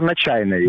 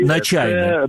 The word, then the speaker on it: Начайний: Театр «Начайний» или «Начайной», як це вимовляється російською? – Театр «Начайний». – «Начайний».